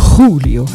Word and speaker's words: Julio: Julio, habe ich extra gesagt, Julio. Wie Chejon.